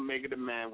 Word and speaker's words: MegaDaMan: Okay, for those that don't know who I am, I go by the name of Mega the Man, which is spelled M-E-G-A-D-A-M-A-N.